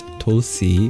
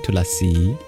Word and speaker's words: Tulasi: You'll often hear it pronounced as just Tulsi without the A, because in schwa deleted languages like Hindi, they remove that sound when it's short. But in Sanskrit, it's Tulasi, three syllables, with the final syllable being long.